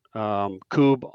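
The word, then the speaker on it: Kubb: There's a great LA Coob Club. They're all over. And really pretty much you just need to search Coob on social media and you're going to find something.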